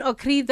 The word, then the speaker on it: Okri: I'm going to butcher his name. – It's Ben Okri, The Famished Road.